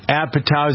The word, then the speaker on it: Apatow: And then I noticed that the announcement was made that John Mayer, filmmaker Judd Apatow, is that how you pronounce his name? A-P-A-T-O-W?